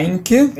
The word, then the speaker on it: Reinke: É, a pronúncia tá quase, a pronúncia é Heinke.